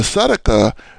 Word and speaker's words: sadaqah: And the sadaqah is spelled S-A-D-A-Q-A-H, sadaqah.